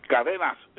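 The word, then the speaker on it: Cadena: Cadenas. It means chain in English. – Okay, cadenas trace. It means chain. – TV. Yeah, chain. – Okay. So that's cadenas trace.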